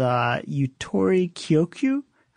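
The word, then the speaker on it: Yutori Kyoku (ゆとり教育): And in response, there was this new educational system developed called Yutori Kyoku. I know I'm pronouncing that wrong. – I think you said it exactly right.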